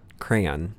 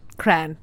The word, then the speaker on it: crayon: Crayon. – Because it is a one-syllable word to me. – It is a two-syllable word to me. – Not to me. I grew up saying crayon. Cran.